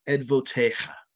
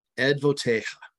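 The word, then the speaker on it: edvotecha (עדותיך): And in Hebrew, it's pronounced edvotecha. Edvotecha. And it's ayin, dalet, vav, tet, yod, and kaf final or kaf sofit.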